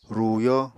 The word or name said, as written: ruyó